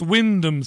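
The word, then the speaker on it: Wymondham: Wimentum is pronounced Wyndham, says Bridget.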